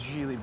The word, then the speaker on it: Gigli: It's uh, it's Gigli, by the way. What? My, my name is G- pronounced Gigli. It rhymes with really.